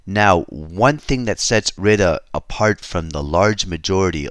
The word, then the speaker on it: Rueda: the region called Rueda, if I'm using a little more English pronunciation. It's R-U-E-D-A.